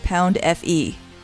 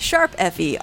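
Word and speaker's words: Tokyo Mirage Sessions ♯FE: Joey Koki writes, "This submission is sourced from the Wii U's Swan Song RPG, Tokyo Mirage Sessions Pound FE, pronounced Sharp FE, oh thank you, developed by Atlas, published by Nintendo, and released June 24th, 2016.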